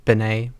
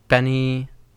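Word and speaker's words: BENEE: Yeah, Benny, who I always in my mind pronounce Benay, but Benny blew up from her song with Gus Dapperton with Super Lonely.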